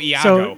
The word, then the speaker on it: Iago: Did I tell you what they were going to do with Iacho. – Iacho? – Oh God. – From Aladdin. – Oh, Iago. – So, yeah, Iago. Sorry, I pronounced it wrong.